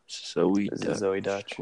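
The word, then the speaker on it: Zoey Deutch: Zoe Dutch. Which, it's spelled weird though.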